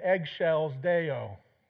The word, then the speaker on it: excelsis Deo: If you're in choir, ever been in choir, and you have to sing this, it's Gloria in eggshells Deo. Okay? That's how you know how to say it.